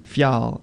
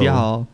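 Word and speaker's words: Fjäll: Fjall. It's spelled FJ. – Edgar Allan Poe. – Fjall.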